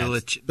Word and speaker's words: Belichick: Not pronouncing Bill Belichick's last name. – Yeah, evidently correctly. There's rumor it's different. – Chick? – Belichick? – Yeah. Belichick.